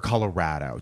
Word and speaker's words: Colorado: Well, or Colorado. Do you know you're supposed to say it like that? – You are?